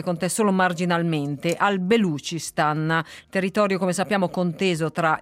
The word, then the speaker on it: Balochistan: Allora, già la pronuncia in realtà ci sono stata, sono confusa tanto quanto voi, nel senso che si chiama Balochistan, è così come lo chiamano la maggior parte delle persone, ma anche lì lo chiamano in modi diversi.